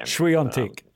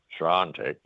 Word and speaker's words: Swiatek: I call it Swastika, but I can't pronounce his second name. – Swiatek. – Swiatek, yes.